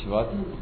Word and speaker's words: Shvat: That's the, apparently the name of the month is Shvat. So if you want to pronounce it precisely, it's Shvat.